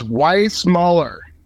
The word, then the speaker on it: Weissmuller: It's Weissmuller.